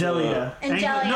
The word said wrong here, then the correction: Anglia: No, I'm sorry. Anglia.